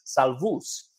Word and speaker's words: Salvus: It's a Latin word, and in the Latin, it's actually pronounced Salvus, which means safe.